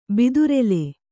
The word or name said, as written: ಬಿದುರೆಲೆ